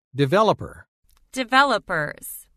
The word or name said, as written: Developer – Developers